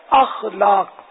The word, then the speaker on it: اخلاق: اخلاق نہیں بلکہ اخلاق، صحیح تلفظ ہے اخلاق.